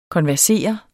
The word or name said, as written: konversere